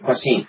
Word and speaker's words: Kwasin: Someone who's somewhat unconventional, even, and it's Kwasin, that's how you pronounce it? – Yes, yep. – Kwasin.